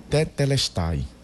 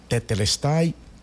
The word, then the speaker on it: tetelestai: Essa palavra, como já tivemos a oportunidade de falar em outro momento, ela é uma expressão grega e ela é pronunciada assim: tetelestai. Tetelestai quer dizer: está cumprido, está quitado, está consumado, está terminado.